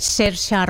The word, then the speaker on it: Saoirse: La actriz es Saoirse Ronan, que creo que se pronuncia algo así, se escribe Saoirse,